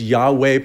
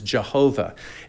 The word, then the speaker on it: Yahweh: It's made up of four consonants referred to as the tetragrammaton, and it's YHWH, most accurately pronounced as Yahweh, probably most popularly pronounced as Jehovah.